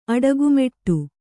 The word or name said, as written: ಅಡಗುಮೆಟ್ಟು